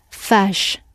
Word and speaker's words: faz: It should, but actually the E at the end has got dropped through overuse and the form is fash. – Fash. – So we drop the E at the end and the Z has got softened because the final Z in Portuguese is pronounced sh.